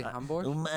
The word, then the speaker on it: Hamburg: Well, in German, it's actually Hamburg. – Which is it? I'm an American. Hamburg is the German pronunciation, right? – Hamburg.